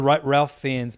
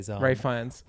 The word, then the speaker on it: Fiennes: No, it's pronounced Rafe. – What the fuck? – Yeah. – What the fuck? – You didn't know that one? – No, I didn't. – Yeah, that's Ray Fiennes. It's like Ref.